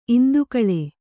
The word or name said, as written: ಇಂದುಕಳೆ